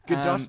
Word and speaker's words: Gadot: Gadot doesn't sound as sexy. – Yeah. – Or doesn't sound as fancy, but that is technically how it's pronounced.